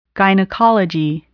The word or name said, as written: gynecology